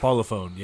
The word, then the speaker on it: Parlophone: It was called Parlophone Records. Am I saying that right? – Parlophone, yes. Yes, I remember that label.